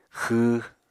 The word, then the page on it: xww: Apatani